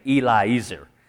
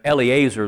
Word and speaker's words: Eliezer: I like to say Eliezer, but Eliezer is how most people pronounce it. But it's E-L-I and then E-Z-E-R, and I just see the Eli and then Ezer, and that's the way I end up saying it.